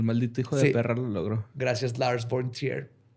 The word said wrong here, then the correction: von Trier: Gracias, Lars von Trier.